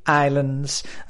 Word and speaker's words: island: I'm here in the beautiful Canary Islands, and listen to my pronunciation, isla in inglés, island with a silent S.